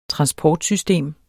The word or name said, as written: transportsystem